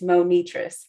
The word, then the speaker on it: Monitris: But if you say Money Trees really quick, that's how you say it. And if you're my mom who's watching, it's Monitris. – Monitris. – Yeah. Like when I'm in trouble, she enunciates everything.